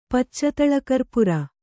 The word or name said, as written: ಪಚ್ಚತಳಕರ್ಪುರ